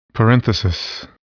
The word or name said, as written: parenthesis